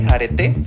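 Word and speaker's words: Areté: Y también tengo por ahí una página en Facebook que se llama Arete, con, como arete de, oreja, pero con acento en la última E, Arete. Y en Instagram me encuentran como guión bajo, guión bajo.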